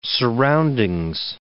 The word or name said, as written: surroundings